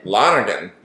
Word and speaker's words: Lonergan: Kenneth Lonergan. – Lonergan.